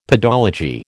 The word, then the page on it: pedology: American English